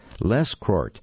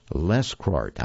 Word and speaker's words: Lescroart: Lescroit. I'm going to spell that name for you because I'm not completely sure I'm pronouncing it correctly. It's Lescroart, John T. Lescroit.